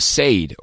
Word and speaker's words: Sade: S-A-D-E. – Is that Sade? – No H in there, so I think it's just Sade.